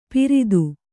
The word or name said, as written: ಪಿರಿದು